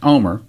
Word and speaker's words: Olmert: Headline, Omer, O-L-M-E-R-T, again, it's pronounced Omer.